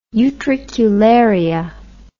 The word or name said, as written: utricularia